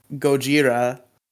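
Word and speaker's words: Gojira (ゴジラ): I guess Kira is like kind of equivalent to like Gojira. – The fuck is Gojira? – That's how they pronounce Godzilla in Japan.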